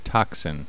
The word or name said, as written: toxin